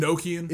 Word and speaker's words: Enochian: In December 1945, Parsons began a series of rituals based on Enochian magic? Is that how you say it? – Enochian? – Enochian magic.